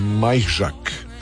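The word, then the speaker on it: Majchrzak: Dona Vekić uzela turnir u Queensu, Londonu, a iznenađujuće Hertogen Bosch uzeo poljski tenisač Kamil Majžak, tako ćemo ga izgovoriti, najbliže točnome. Majžak,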